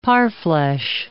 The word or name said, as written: parfleche